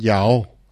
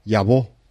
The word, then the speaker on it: llavor: Després, els orientals, és a dir, Barcelona-Girona, diuen llavó, perquè la R final no la pronuncien. Al nord de Castelló fan el mateix que els orientals, la llavó. No diuen la llavor, diuen la llavó.